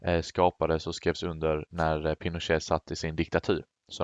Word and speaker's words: Pinochet: Augusto Pinochet. – Ja, precis. Ja. – Jag vet inte hur du uttalade det. Det var lite fransk accent på den. – Ja, Pinochet.